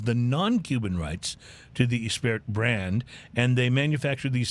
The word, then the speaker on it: Gispert: It's the Gispert, which is spelled like Gispert, G-I-S-P-E-R-T. But I think it's pronounced Gispert.